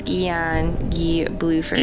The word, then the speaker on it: Guy Bluford: It was either it's Guy Bluford. Or Guy Bluford. – Nope, it's Guy. – Junior. – It's Guy Bluford.